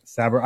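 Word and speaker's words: Sabrett: A friend of mine grew up in New York, and I said, Sab, or what is Sabre. – I used to say it wrong, and you started laughing at me. I'm like, well, that's how it's spelled.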